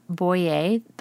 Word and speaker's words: Boyer: Boyer. That's my last name, and it's spelled B-O-Y-E-R.